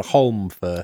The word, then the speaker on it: Holmfirth: It is spelt with an L. – So Holmfirth. – Holmfirth. Yes. You have to say it with that emphasis. Holmfirth. Where are you from? I'm from Holmfirth. Say it like that. Yes, that's how you pronounce it.